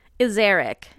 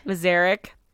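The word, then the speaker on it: Ezarik: How do you say her last name? – Azaric. – Azaric.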